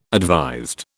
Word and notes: advised: British English

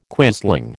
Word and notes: quisling: American English

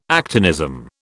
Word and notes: actinism: British English

